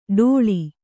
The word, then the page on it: ಡೋಳಿ: ḍōḷi